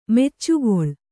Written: ಮುಚ್ಚೆಗೊಳ್

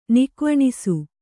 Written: ನಿಕ್ವಣಿಸು

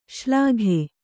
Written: ಶಲಾಕೆ

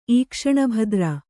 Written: ಈಕ್ಷಣಭದ್ರ

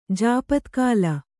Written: ಜಾಪತ್ಕಾಲ

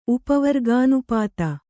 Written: ಉಪವರ್ಗಾನುಪಾತ

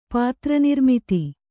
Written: ಪಾತ್ರನಿರ್ಮಿತಿ

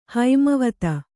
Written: ಹೇಮಾವತಿ

